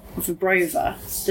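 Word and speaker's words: Wyborowa: First and foremost, I actually wanted to ask you a question about Wybrowa. Did I pronounce that right? – Yes.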